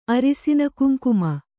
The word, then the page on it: ಅರಿಸಿನಕುಂಕುಮ: arisinakuŋkuma